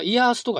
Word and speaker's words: IaaS: で、次のですね、 IaaS っていうのは何かっていうと、これはですね、インフラストラクチャーアズアサービスの略で IAAS と書いて、まあ、イヤースとかですね、 i a ヤースっていうふうに呼ばれてますね。